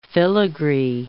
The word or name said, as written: filigree